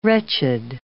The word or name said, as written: wretched